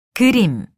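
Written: 그치다

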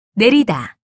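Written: 년

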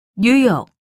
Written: 뉴욕

